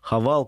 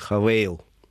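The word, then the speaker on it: Haval: Во-вторых, они делают упор на свою премиальную марку «Хавал», которую они произносят «Хавейл». – Угу решили Хавейл здесь продавать, но я уже говорю, что перспективы китайского внедорожника от 2 миллионов, мне кажется, несколько здесь туманны.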